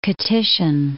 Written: Kittitian

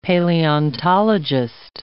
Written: paleontologist

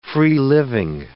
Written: free-living

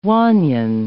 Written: wanion

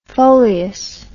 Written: folious

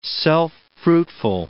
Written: self-fruitful

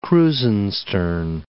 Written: Cape Krusenstern National Monument

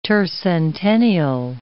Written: tercentennial

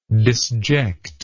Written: disject

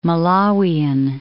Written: Malawian

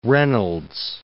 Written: Reynolds

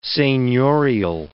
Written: seignorial

